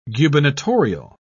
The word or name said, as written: gubernatorial